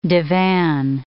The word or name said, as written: divan